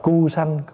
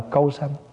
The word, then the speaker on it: Câu Sanh: Có khi đọc là Cu Sanh, có khi đọc là Câu Sanh, nhưng mà thường mình đọc Câu Sanh.